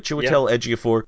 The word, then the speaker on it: Chiwetel Ejiofor: Chiwetel Ejiofor. I like Chiwetel Ejiofor in anything he does.